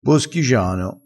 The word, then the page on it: boschigiano: dialettale